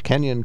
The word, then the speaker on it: Kenyon: Kenyon College, am I saying that right? – That's correct.